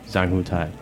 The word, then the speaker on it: Zhang Hongtai: Zhang Hongtai.